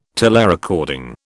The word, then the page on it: telerecording: British English